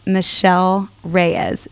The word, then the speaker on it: Michelle Reyes: So I want you to welcome to the show our guest, Michelle Reyes.